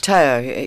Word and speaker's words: Teo: The first artist that you have selected, pronunciation is Teo, actually, because his name is Mateo.